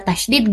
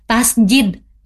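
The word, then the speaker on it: tasydid: Karasi kalau misalnya ada tasdid gimana? Dibacanya tasdid ya, bukan tasjid ya. Tasdid.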